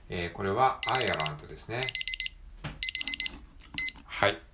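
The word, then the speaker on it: Ireland: あーんと、どっかに載ってたっけちょっと今発音記号を確認してますけど、えー、っと、あアイアランド、アイアランドか。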